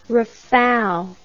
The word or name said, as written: rafale